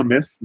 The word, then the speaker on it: Metz: Donc je suis à Metz, pas Metz. Comme certains peuvent prononcer, c'est bien Metz.